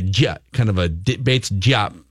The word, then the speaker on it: Diop: This Bates Diop is that how you pronounce Bates? – Diop. – Bates Diop. Bates Diop. – Diop. – Yeah. I think we're going Diop now.